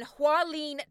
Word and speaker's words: Hualien: Now, Tim, you've also spent time in Hualien, Taiwan. Have I said that right? – That's very close. I think it's as right as I can say it.